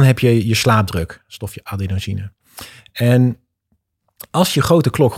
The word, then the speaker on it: adenosine: Kijk, melatonine hebben we allemaal wel eens van gehoord, maar we hebben ook adenosine, zeg ik het goed? – Ja, adenosine.